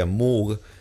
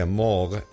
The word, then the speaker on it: Moog: El instrumento había sido inventado por Moog en 1958, no se pronuncia Moog, se pronuncia Moog,